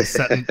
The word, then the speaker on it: Stoughton: You gotta say Seton. You gotta say Seton DeMai.